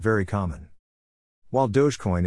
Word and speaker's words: Dogecoin: The name Dogecoin has become a source of controversies among users over the years, though co-founder Jackson Palmer says it is pronounced Dogecoin.